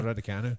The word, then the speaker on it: Raducanu: Well, on September 11th, Emma Raducanu, I'm pronouncing that right, haven't I? – That is right, Emma Raducanu.